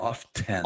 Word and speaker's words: often: Correct, which we wear often. – Often. Yes. Often. – You know, the T is silent like the P in swimming. – What? Often? – O-F-T-E-N.